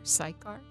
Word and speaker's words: Sychar: Jesus came to a town of Samaria called, now how do you pronounce, S-Y-C-H-A-R, Sychar?